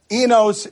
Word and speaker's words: Enos: Enos is how they're pronouncing it.